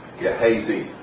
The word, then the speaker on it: Gehazi: Gehazi is how I'm told it's pronounced.